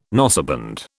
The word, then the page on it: noseband: British English